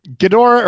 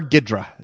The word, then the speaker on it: Ghidorah: Is that right? – Ghidorah or Ghidra, depending on your pronunciation.